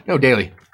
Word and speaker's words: Daly: Am I saying that right? Daly? – Yeah. Connor Daly. – Or is it Daly? – Daly.